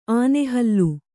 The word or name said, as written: ಆನೆಹಲ್ಲು